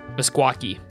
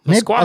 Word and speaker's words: Meskwaki: M-U-S-K-U-A-Q-U-I-E? – There's no Q. M-E-S-K-W-A-K-I. Mesquaki. It's spelled like mess... Guaki, like it's not even. – It's spelled how it sounds.